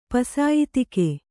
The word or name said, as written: ಪಸಾಯಿತಿಕೆ